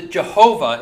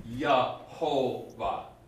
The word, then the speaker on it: Jehovah: Another side note, sorry, but the Jehovah is the same name. – Right. – It's a different way of people pronouncing it. So instead of Yahweh, you get Yahovah. It's different vowels that have been put in there. And, you know, a J instead of a Y.